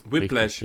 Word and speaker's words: Whiplash: Den, genau, Whiplash. Richtig. Whiplash ist die korrekte Aussprache.